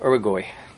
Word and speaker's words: Uruguay: There was a Yid, Rav Moshe Troib, that he came to Uruguay. Is that how you pronounce it? South America? Mm-hmm. Uruguay.